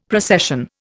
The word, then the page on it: procession: Indian